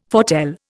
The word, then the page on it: foretell: Indian